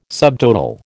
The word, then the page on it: subtotal: American English